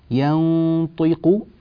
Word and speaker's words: ينطق: ينطق، النون غنتها مفخمة لأجل الطاء، الطاء مفخمة وهي مكسورة وينبغي إعطاؤها حقها من التفخيم، ينطي طي